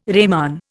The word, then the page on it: reman: Indian